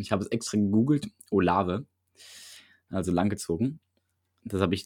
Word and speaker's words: Olave: Ich gehe davon aus, dass Olave, so spricht man den Namen richtig aus, ja.